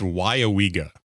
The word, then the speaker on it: Weyauwega: So I was smiling a little bit because one of the times you pronounced it, you pronounced everything but the first syllable correctly. It's Weyauwega.